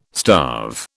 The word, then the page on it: starve: British English